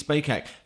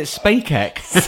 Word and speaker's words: Spacek: She's not the only woman in the film, though, because elsewhere, Jim Garrison's wife, Sissy Spacek, who... – Spacek? – Is it Spacek? – Isn't it Sissy Spacek? – Is it SpaceX? I've always pronounced it SpaceX.